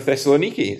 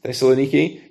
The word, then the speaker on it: Thessaloniki: You'd have to go to the Greek town of Thessaloniki. Is that, am I saying that right? Thessaloniki.